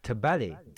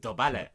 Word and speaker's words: dobale: Tabale. – Dobale. – Is it a D like? – Yeah, dobale. I think so. Yeah, that's how you pronounce dobale.